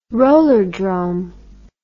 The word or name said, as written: rollerdrome